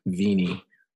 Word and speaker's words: Vina: She is small, short like me, 5'2", and her name is spelled Vina, but it's pronounced Vini.